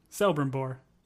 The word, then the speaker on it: Celebrimbor: Not Cel, I think it's Kel. – It's Kel. It's with a K. – Okay. Well, it's spelled with a C. It's a C-E, man. – It's a hard K, Celebrimbor.